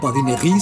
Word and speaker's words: Badinerie: Badinegui se escribe Badinerie y solemos pronunciar en, bueno, castellanizado, Badinerie en español.